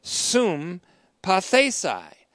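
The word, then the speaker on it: sum pathesai (συμπαθῆσαι): And that word in the Greek is actually sum pathesai. Sum, S-U-M, and then P-A-T-H, long E, S-A-I. Sum pathesai.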